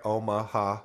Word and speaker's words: Omaha: It has three syllables, right? Omaha.